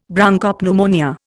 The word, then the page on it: Bronchopneumonia: Indian